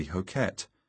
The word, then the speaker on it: Hoquette: Now the Humane Society learned the dog's name was actually Hoquette, according to the correct pronunciation in the dialect Hokkien, the language of the tanker's captain.